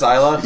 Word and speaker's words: Isla: Is it pronounced Isla? – Yeah.